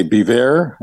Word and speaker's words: Biewer: Odell is a Bever, and it's spelled B-I-E-W-E-R. That's B-I-E-W-E-R.